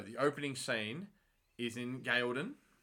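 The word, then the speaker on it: Gaeldan: And I can say Gaelden because they said it in the first episode, so I know the correct pronunciation.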